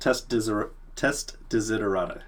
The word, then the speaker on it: TestDesiderata: TestDesiderata. It's a mouthful, but you can just start typing test D-E-S, and then Google usually fills in the rest.